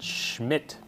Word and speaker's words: Smith: Well, I have the book right here, Physics and Vertical Causation by Wolfgang Smith, or better to pronounce it Schmidt, because he's an Austrian with a heavy German accent.